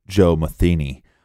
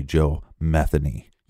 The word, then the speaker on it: Joe Metheny: They're spelled the same way, but and if you look up some reports, they will say Joe Metheny, right. But it's actually Joe Metheny. – Yeah, we got that from local Baltimore reports. So him being from Baltimore, we're going with that pronunciation.